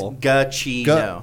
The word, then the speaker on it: Giacchino: I think on his Twitter he has it written out phonetically and I think it's Giacchino. – Giacchino, okay.